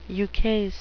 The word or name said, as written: ukase